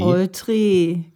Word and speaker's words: Oltre: Oltre. Wie man auch mal das ausspricht mit doppel, doppeltem Akzent. – E. Oltre, genau. Oltre. Lang e.